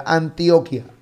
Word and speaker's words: Antioquia: Antioquia.